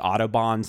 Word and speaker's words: Audubon: It's like the Audubon Society.